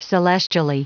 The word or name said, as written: celestially